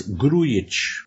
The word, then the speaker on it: Grujic: You pronounce the, unlike What's-His-Face on the LFC commentary, you pronounce the J as a sort of soft Y. So it's Grujic.